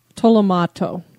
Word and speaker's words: Tolomato: The Tomo, how do you say it again? – Tolomato.